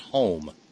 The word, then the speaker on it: Qom: Qom is pronounced home. Thank you, Ron and Raleigh. I'll be dipped. That's that silent HQ, QH, home.